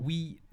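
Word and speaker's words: oui: French, man. Wee. It's not like oi. It's not oi. – Oh no, I've been pronouncing it as oi. – No, it's wee.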